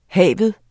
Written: have

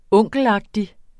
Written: onkelagtig

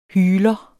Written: hyler

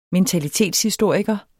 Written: mentalitetshistoriker

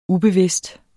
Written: ubevidst

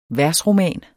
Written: versroman

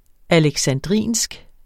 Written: alexandrinsk

